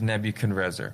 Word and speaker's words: Nebuchadrezzar: have the families of the north and Nebuchadrezzar. – Mm-hmm. – And I'm saying it like that because that's how it's pronounced in Jeremiah here. That's how it's spelled in Jeremiah.